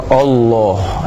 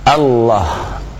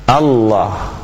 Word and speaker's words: Allah: Apalagi kalau dia jadi muadzin, ya, diucapkannya dengan misalnya dengan memonyongkan kedua bibir sehingga bukan dengan fathah sehingga dengan tebal o, ya, sehingga diucapkan Allah. Nah, ini keliru, ya, kita harus membuka mulutnya a, Allah, Allah, ya. Nah, begitu cara mengucapkannya yang benar, wallahu a'lam.